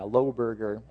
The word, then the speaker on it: Loberger: I remember that there were, his last name was Loehberger, something like that. Loberger is how it's pronounced. And I was Loehberger.